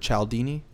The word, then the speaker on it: Cialdini: I love Influence by Robert Cialdini, or Cialdini is the way it's pronounced.